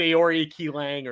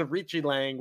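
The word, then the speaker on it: Aori Qileng: Oh, yeah, I said Aori Ki Lang or something like that. Yes. But it's Arichi Lang,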